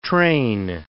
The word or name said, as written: train